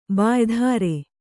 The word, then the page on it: ಬಾಯ್ಧಾರೆ: bāydhāre